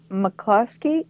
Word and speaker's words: McCluskey: And a guy I just found and discovered a couple days ago, his name is Taylor McCluskey, M-C-C-Lusky, C-L-U. – Rhymes with an Osky.